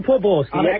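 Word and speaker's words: Poborský: Carol Poborski. Accent on the paw here.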